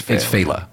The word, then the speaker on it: Failla: And you pronounce it the way every anchor on Fox News pronounces it. – Yeah. – It's Failla. Yeah, yeah, yeah.